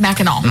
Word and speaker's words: Mackinac: It's kind of like Kinnanook, whatever that was up north. – The island. – Mackinac. – Spelled Mackinac, said Mackinac.